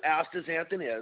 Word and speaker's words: astaxanthin: It's a pigment, and it's spelled A-S-T-A-X. So the astaxanthin, the X is pronounced like a Z, A-N-T-H-I-N. The xanthin just has an X instead of a Z. And if you remember that, it sounds like a Z, but it's really an X, then it's pretty easy to spell out and sound out astaxanthin.